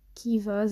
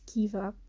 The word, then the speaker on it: Keeva: Isn't it spelled K-E-E-V-A? Dus, allee, ja. Ik denk dat dat zo is pronounced Kiva.